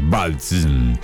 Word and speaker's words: Balsm: Y está bien dicho, ¿eh? Se escribe Bal, ¿sí? Y después THB corta S, Balsm.